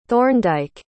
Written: thorndike

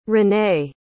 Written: renae

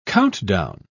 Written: countdown

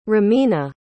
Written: romina

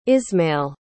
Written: ismail

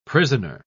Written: prisoner

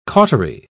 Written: cautery

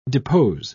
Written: depose

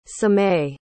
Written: sameh

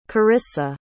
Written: Corissa

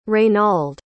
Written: reynald

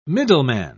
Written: middleman

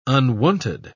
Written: unwonted

